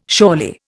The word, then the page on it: surely: Indian